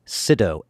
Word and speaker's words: Sydow: I double-checked, and I take pride in pronouncing people's names correctly, maybe because my name is Sido, S-Y-D-O-W, and it gets pronounced Sido and Sidow and Sidow and Snyder and all kinds of different things.